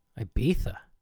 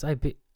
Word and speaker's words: Ibiza: Ibiza? What's Ibiza? Oh, you mean Ibiza.